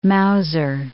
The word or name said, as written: Mauser